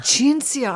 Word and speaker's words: Cinzia: Cinzia. I wondered if it was a ch or a s. – Yeah, Cinzia. Italian. So the C-I yeah. is pronounced as ch.